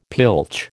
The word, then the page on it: pilch: American English